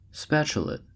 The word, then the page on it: spatulate: American English